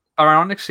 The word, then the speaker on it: Baryonyx: I like the Baryonyx. Is that how you pronounce it? – Baryonyx, yeah. Okay, yeah.